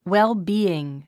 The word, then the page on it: well-being: wélbíːɪŋ; ウェルビーン